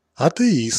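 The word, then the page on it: атеист: Russian; ateist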